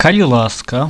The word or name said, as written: kali laska